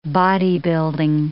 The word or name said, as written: bodybuilding